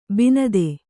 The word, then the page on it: ಬಿಣ್ಣಿತು: kannada